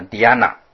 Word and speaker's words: dhyana: 那印度的梵文呢，它的发音，正确的发音叫 Dhyana 啊。